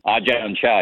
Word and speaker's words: Ajahn Chah: Ajahn Chah, is that right? – Ajahn Chah, yeah, you were pretty close.